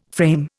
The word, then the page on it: frame: Indian